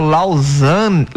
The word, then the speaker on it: Lausanne: Qual é a pronúncia aqui? É o Lausanne.